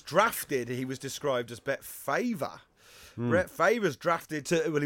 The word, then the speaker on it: Favre: Brett Favre.